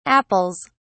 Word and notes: apples: British English

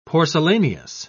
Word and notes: porcelaneous: British English

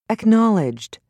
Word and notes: acknowledged: British English

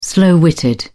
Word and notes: slow-witted: British English

